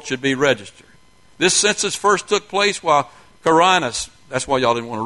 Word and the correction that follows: Quirinius: Quirinius, there we go, was governing Syria.